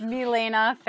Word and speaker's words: Milaena: I don't want to rain on your parade, but it's Milaena Ferrarese.